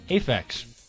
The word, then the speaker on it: Aphex: You love, how do you say it? – Aphex. – Aphex Twin. Okay. – With an A.